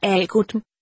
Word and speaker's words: Agudm: Hans navn er Agudm, udtalt med stærk A-lyd på første stavelse.